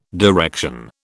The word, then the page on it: direction: British English